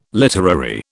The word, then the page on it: literary: British English